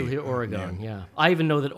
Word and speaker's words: Oregon: I know it's pronounced Oregon, not Oregon.